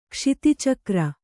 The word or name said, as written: ಕ್ಷಿತಿಚಕ್ರ